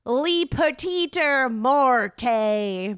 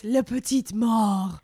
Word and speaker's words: La Petite Mort: I don't think that's how you pronounce it, but I'm not French. – It's La Petite Mort.